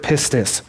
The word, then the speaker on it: pistis: But what Paul uses in this scripture right here in the Greek, he uses the word pistis. And I looked it up. That's how you pronounce it.